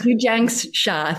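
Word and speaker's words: Zhi Gang Sha: Dr. and Master Zhu Jiang Sha. I feel like I'm saying her name wrong. Can you correct me again? – Zhu Jiang Sha.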